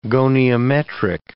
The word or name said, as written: goniometric